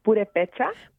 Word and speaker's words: Purépecha: Am I pronouncing that right? – It's Purépecha.